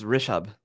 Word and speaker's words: Rishabh: Yeah, it's Rashibh.